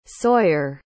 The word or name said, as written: sawyere